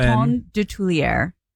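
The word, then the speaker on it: Coton de Tuléar: Is it spelled like cotton? How do you spell it? – One T. C-O-T-O-N. – Coton? – C-O-T-O-N. – Coton de Tulière. – How the hell do you spell that? – Of Tulière. D-E-T-U-L-E-A-R.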